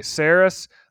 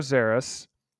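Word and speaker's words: Szeras: I used to say Zaris. I don't know. They say Illuminor Zaris with like a Z. That's what I hear. So Illuminor Zaris. That's actually how to say his name.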